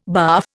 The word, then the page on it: barf: Indian